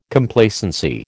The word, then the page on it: Complacency: American English